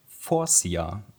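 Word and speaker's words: Faurecia: Und da ist es tatsächlich Forcia. Also das E ist komplett stumm.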